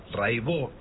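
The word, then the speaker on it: Raybaut: Buenísimo, Cata Raibó. Se escribe Raibaus, métanse, búsquenla, Rai con Y, Baut con B larga. Catalina Raibaut, Raibó se dice, y bueno, cotidiano son 10 canciones, hay un documentalito muy lindo.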